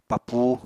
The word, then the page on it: papuu: Apatani